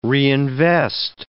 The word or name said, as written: reinvest